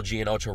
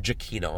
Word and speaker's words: Giacchino: But like, just in terms of homage, the score Michael Giacchino wrote this time, or Giacchino, I'm sorry, I mispronounced his name.